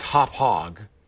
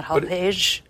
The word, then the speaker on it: Hauppauge: I believe it's spelled H-A-U-P-P-A-U-G-E. – Hopog? – It's pronounced Hopog. – Not Hopage. – Hopage. But it's spelled H-A-U-P-P-A-U-G-E, I think.